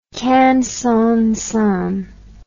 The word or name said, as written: kangsongsan